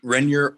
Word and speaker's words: Renyer: Renyer, R-E-N-Y-E-R.